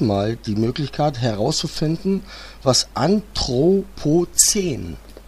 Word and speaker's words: Anthropozän: Das neue Album heißt ja Störsender Anthropozän.